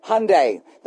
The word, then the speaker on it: Hyundai: No, I did not mispronounce it. Sunday, Hyundai, that's how they pronounce Hyundai, Tournament of Champions.